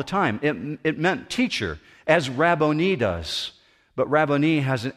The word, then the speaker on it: Rabboni: She's now consumed with victory, and all she can say is, "Rabboni." Now, the reason I pronounce it that way is very specific.